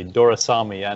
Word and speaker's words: Doraisamy: Doraisamy, and in all candidness, I had difficulty pronouncing my own name for the most longest time, so you did it really well.